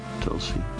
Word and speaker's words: Tulsi: So you got this lady's name, and it's T-U-L-S-I? – Yeah. – And how do you say that? Tulsi? Tulsi? – Tulsi. Tulsi. – All right. Tulsi Gabbard. – Like Tulsa, but with an I.